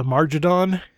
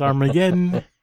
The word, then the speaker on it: Armageddon: And, for the longest time, I thought the episode was called, Taste of a Margadon. Because I didn't know how to pronounce Armageddon.